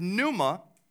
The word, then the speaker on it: pneuma: In the New Testament, which is written in Greek, it is the word pneuma. The P is silent.